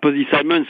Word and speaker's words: Posy Simmons: Il y a aussi le retour de, j'espère que j'ai pas écorché son nom, Posy Simmons. – Ah, Posy Simmons, oui, c'est formidable.